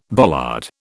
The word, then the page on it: bollard: British English